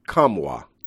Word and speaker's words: Nkamhoua: Now, if you see his name spelled N-K-A-M-H-O-U-A, it's pronounced Kamwa.